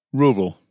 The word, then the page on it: rural: British English